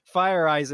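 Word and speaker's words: Feyereisen: Fire Eyes, and I think that's how you pronounce it. I'm not trying to say it wrong. – I believe that is how you pronounce it, yeah.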